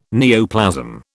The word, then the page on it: neoplasm: British English